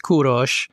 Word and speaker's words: Kurosh: It is Kurosh, so we don't need to roll the R or anything else.